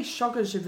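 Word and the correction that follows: Solskjær: Whatever, yeah. How have you pronounced his name? Yeah. Ollie Solskjaer. – Yeah, yeah, yeah.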